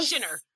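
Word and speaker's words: Schinner: So she's an award-winning vegan celebrity chef, Miyoko Shinner. Sinner. We talked about this before. Miyoko Shinner. – Shinner. Okay, Miyoko Shinner. Earlier we were like, how do I pronounce her last name?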